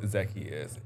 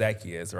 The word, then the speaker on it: Zaccheus: It's either way. So it's the story behind that. My mom says Zaccheus, right? That's how she pronounced it. But of course, there's a biblical song along the way. It says Zaccheus was a wee little man. So people say Zaccheus.